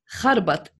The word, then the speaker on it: Kharbat: Reem Kharbat. It's in Arabic. So KH.